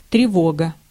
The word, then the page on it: тревога: /trʲɪˈvoɡə/